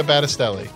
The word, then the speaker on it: Battistelli: Francesca Battistelli. – Yeah. – Say it together, people. – Francesca Battistelli. – I want to say Battistelli. – Not Mastaccioli. Battistelli.